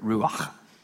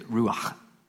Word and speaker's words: ruach: but is the word ruach. I'm not making that up. That is how you pronounce it. That one is it's accurate. But it is ruach.